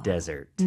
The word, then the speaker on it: desert: S가 두개 들어갑니다, 여러분. S 하나만 쓰시면 안 돼요. 그러면은 사막이라는 뜻이 되죠. – Desert. – 네, 발음도 다르고요.